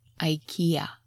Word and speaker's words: IKEA: And yes, that's how Americans pronounce it, IKEA.